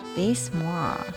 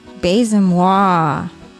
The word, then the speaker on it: Baise-moi: Baise-moi. – Moi is me. – Baise-moi. – Baise-moi? – Baise-moi. – Say it like you mean it. – Baise-moi.